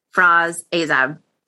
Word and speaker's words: Frass Azab: the dear Frass Azab.